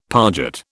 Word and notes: parget: British English

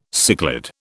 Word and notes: cichlid: British English

